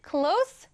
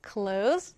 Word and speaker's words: close: 네, 1번은 close the door, 2번은 close the door인데요. 네, 정답은 2번입니다. 닫다라는 의미로 쓰일 때는요, close로 발음하는 것이 맞아요.